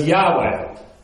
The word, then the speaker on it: Yahweh: Yahweh.